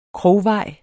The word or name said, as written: krogvej